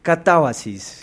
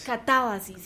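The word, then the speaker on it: Catábasis: Justamente él viene a presentarnos su obra Catabasis. Bienvenido, ¿cómo estás? ¿Sí lo dije bien? – Casi perfecto, es Catabasis. – Catabasis, sí, ¿ves? – Con la acentuación en la segunda.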